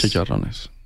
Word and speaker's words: chicharrones: So everybody needs to go to the Arizona Sands Club and order the chicharrones. – Chicharrones. – I know I butchered it. – No, that's pretty good. – It's good enough. It's good. It's understandable.